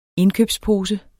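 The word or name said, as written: INDKØBSPOSE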